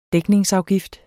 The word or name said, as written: dækningsafgift